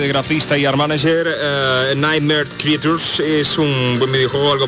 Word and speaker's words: Nightmare Creatures: Es Nightmare Creatures, aunque la verdad es que mi inglés es bastante de Cáceres, así que vamos a pedir a nuestro colaborador esencial que traduzca realmente cómo se pronunciaría esto. Carlos, por favor, ¿cómo se traducen las criaturas de las pesadillas estas? – Nightmare Creatures. – Ahí está. Es impresionante.